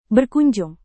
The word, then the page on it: berkunjung: Indonesian